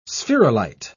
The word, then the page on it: spherulite: British English